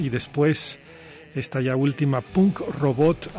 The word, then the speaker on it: Punk Robot: Punk Robot, me hace falta pronunciar bien la K.